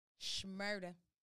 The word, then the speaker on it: Shmurda: and our boy Bobby Shmurda, Shmurda, my girl was getting on me about not pronouncing the SH and Shmurda.